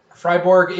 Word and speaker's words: Freiburg: Freiburg is the way that you speak it in German. – Hmm. – In English, it would be Freiburg, but it's Freiburg in German.